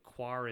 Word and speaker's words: Quar: It's Q-U-A-R, so Quar.